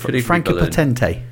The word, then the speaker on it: Franka Potente: Franka Potente. – Franka Potente, thank you. I'm glad you pronounced it correctly because I was guessing.